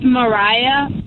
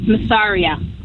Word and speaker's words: Mariah: Her name is pronounced Mariah. But we put a silent S in it, so everybody says Masaria.